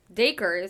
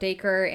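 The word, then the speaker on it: Dacre: It's D-A-C-R-E. Daiquiri? Anyways, Daiquiri is how it says online. So we'll say just Daiquiri, I guess.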